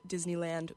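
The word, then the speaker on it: Dizz Knee Land: It's spelled Diz, like dizzy, and then knee, you know, like that thing for your leg, and then land.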